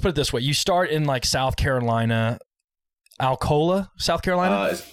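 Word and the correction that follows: Alcolu: It's pronounced Alcalo, actually. It's a little bit different. Kind of got like that Southern slang to it.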